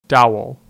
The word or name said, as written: Dowell